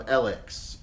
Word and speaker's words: ELEX: It's spelled E-L-E-X. – E-L-E-X, all capital letters. – Oh yeah, that's important. – And if it was Japanese, it would be ELE cross.